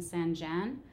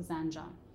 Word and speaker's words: Zanjan: Um, so that kind of stuff was a challenge, or expressions, or even Zanjan, even though it's spelled with a J A N, I would have said Zanjan. She's like, no, it's like a, the name John, and I'm like, oh, okay, so Zanjan.